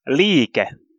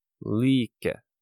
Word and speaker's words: liike: Movement. – Liike. – Liike. So that sounds a lot like play.